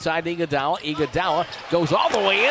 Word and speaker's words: Iguodala: And Andre Iguodala's, I know I butchered that name, dunk. Do you remember how that's pronounced, Gloria or Elisa? – Iguodala.